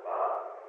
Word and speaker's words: Mahan: How do you pronounce it? – Mahan.